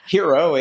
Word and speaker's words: Hero: well, you know, you know, Hero, H E R O.